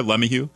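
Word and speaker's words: LeMahieu: But Devin Lemieux, did I pronounce it right there? Lemieux? – Yep. – Okay. – That's right. Yeah.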